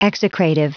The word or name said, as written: execrative